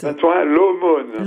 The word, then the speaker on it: Saint-Ouen-l'Aumône: Saint-Ouen-l'Aumône.